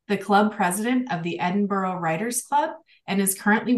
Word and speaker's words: Edinburgh: The real pronunciation, if you want to pretend that you're kind of local, is Edinburgh.